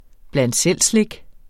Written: bland selv-slik

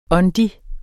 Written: åndig

